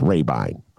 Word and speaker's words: Rabine: His correct pronunciation of his name is Rabine. All right, don't mess it up.